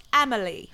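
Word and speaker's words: Amélie: It's actually pronounced Amelie.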